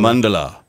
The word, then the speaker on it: mandala: Mandala. – But I always thought it was mandala, but it's mandala.